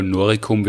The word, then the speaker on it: Noricum: Ja, das ist umstritten, aber ich glaube wahrscheinlich in dem Fall Noricum wird man es mit K aussprechen. Genau, also das ist das Halobacterium noricense.